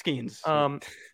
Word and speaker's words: Skenes: I really hope I'm pronouncing that right. I've never actually heard it pronounced. Pretty sure it's Skenes. – Yeah, it's Skenes.